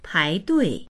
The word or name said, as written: pai2dui4